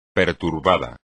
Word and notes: perturbado: espagnol

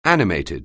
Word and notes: animated: anglais

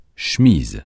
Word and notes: cheminot: français

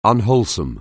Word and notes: unwholesome: anglais

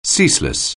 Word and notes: ceaseless: anglais

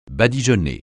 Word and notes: badigeonner: francais

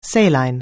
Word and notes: saline: anglais

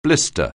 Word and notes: blister: anglais

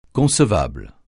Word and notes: concevable: francais

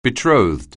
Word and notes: betrothed: anglais

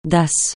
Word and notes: Ddass: francais

